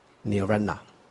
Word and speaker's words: 涅槃: 个是如去，这我们这个释文里面有讲说如去，这如去的意思就是说乘真如之道而去，这个去就是入于无上大涅槃，叫做如去。这如是真如的意思。这涅槃呐，梵文是涅槃呐，涅槃呐。这有人问说，是念涅槃，是涅槃还是涅槃？因为很多人念成涅槃，念涅槃是错的，是有边念边。这个涅槃，这是盘呐、这是盘，它下面这个木字旁呢是念班啊、那个下面木字旁那个就跟念跟这个班是一样的啊、所以念涅槃是不正确的。